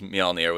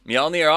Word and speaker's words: Mjolnir: It's a soft J, like yogging. – Yeah, I actually, if Manta had taken Excalibur like I thought he was, Mjolnir was going to be my next pick. – See, that was perfect pronunciation.